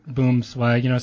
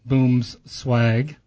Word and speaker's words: Boomswag: Well, Clay, tell us a little bit more about Boomswag, some of your products. I'm looking at your brochure here in front of me, and you can get more information on Boomswag. I keep saying that wrong.